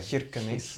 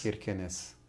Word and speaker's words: Kirkenes: Kirkenes. I like how you say that. That's very Kirkenes. – Kirkenes. – Yeah. Yeah, that's how they say it, supposedly.